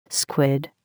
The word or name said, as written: squid